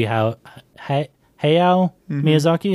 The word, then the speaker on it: Hayao Miyazaki: Oh, the fucking writer and director of this movie, Hayao Miyazaki, is that how you say his name? – Yeah.